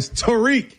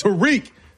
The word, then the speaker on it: Tarik: First of all, put some respect and say his name right. Tarik. – Whatever. Tarik Skubal is what I call him. – That's what everybody taught. Tarik. It's Tarik. Sound like he was in the hood.